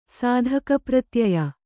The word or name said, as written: ಸಾಧಕಪ್ರತ್ಯಯ